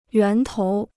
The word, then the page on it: 源头: yuán tóu